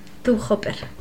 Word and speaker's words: Tobe Hooper: Bueno, Master of Horror cuenta con grandes directores como John Carpenter o Tobe Hooper. ¿Lo he dicho bien? – Sí. – Tobe Hooper.